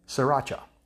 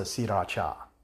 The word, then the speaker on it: Sriracha: and it's called Sriracha. Sriracha. But they pronounce it Sriracha.